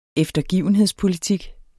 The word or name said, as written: eftergivenhedspolitik